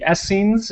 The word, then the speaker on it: Essenes: And he goes into discussing about the Essenes. Is that how you say it? – Yeah.